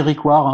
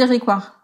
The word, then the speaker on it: Ryckewaert: Donc, ben, Franck, alors ça se dit Ricoire, hein, on a on peut dire Rigvart aussi. mais Voilà, il y a plusieurs prononciations, mais euh, mais voilà. – Toi, tu dis Riquard.